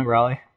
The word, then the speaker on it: Raleigh: Is that how they say it down there? Raleigh? – No, Raleigh. – Oh. – Like, R-O-L-L-Y.